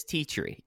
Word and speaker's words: Tea Tree: Now, do you know how to pronounce this company's name that is sponsoring this 8-man tag match? – Yes, it is one of Yoshino's most famous sponsors. It is Tea Tree. They do customized shoes. – That makes sense. T-T-R-E makes sense that it's Tea Tree.